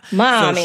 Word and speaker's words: Marmee: So she would have probably pronounced Marmee as mommy.